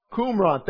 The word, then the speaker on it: Qumran: And I think it's Qumran, if I pronounce that correctly. Q-U-R-A-M. Qumran, thank you.